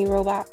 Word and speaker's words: Robach: TJ Holmes and Amy Robach. That's how you pronounce it. Amy Robach.